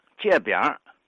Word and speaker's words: 隔壁: 北京方言说成是钢声，比如老天津呢，把隔壁读成借边把隔辈人读成借辈人，把隔着门缝看人读作接着门缝看人。